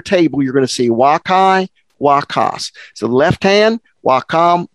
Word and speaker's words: vaccae: So wakai, V-A-C-C-A-E, wakai, pronounced I, even though it's A-E, wakai.